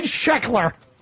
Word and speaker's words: Sheckler: My name is Ted Sheckler.